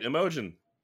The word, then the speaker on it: Imogen: You're right, Imogen. – Oh, it is a soft G.